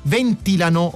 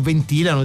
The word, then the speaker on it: ventilano: Nell'alveare la temperatura resta quasi costante intorno ai 35 gradi centigradi e le api ventilano, o ventilano, dipende da dove volete mettere l'accento, battendo le ali come piccoli condizionatori naturali.